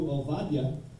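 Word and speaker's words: Ovadia (עובדיה): And pronounced in the original Hebrew, Ovadia, right,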